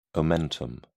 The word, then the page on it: omentum: British English